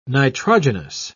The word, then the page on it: nitrogenous: British English